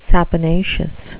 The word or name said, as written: saponaceous